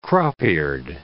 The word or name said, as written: crop-eared